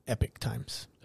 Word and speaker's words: Epoch Times: So Epic Times or Epoch Times? – I believe they still call it Epic Times. – Oh, okay. – They spell it like Epoch, though. – That's probably Epoch. – But it's pronounced Epic Times.